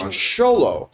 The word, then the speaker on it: Xolo: Then came a movie I'm very, very interested in, which is Blue Beetle, which, by the way, I learned I've been pronouncing Zolo's name improperly. It's Xolo.